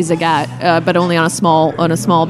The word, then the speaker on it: Zagat: It's pronounced Zagat. – Zagat, like the cat.